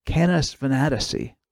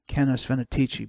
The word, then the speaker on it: Canis Venatici: Sometimes you'll hear it called Canis Venatici, although I like the pronunciation Canis Venatici better.